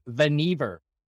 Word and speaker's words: Vannevar: Oh, by the way, By the way, just so you know, on the trivia, it's Vannevar.